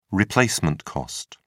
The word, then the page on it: replacement cost: British English